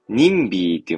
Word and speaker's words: NIMBY: NIMBY、ニムビーとでも読むんですかね。ちょっと読んでみしょ 102 ページなんですけど。ニンビーって読むんですね。